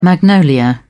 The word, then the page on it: magnolia: British English